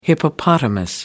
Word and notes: hippopotamus: American English

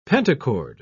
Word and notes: pentachord: British English

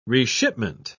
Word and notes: reshipment: British English

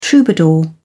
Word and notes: troubadour: British English

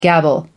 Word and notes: gabble: American English

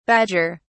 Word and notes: badger(s): British English

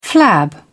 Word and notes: flab: British English

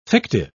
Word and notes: fictive: British English

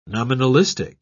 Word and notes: nominalistic: British English

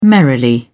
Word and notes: merrily: British English

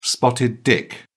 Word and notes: spotted dick: British English

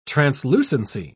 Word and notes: translucency: British English

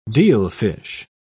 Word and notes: dealfish: British English

